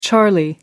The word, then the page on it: charlie: American English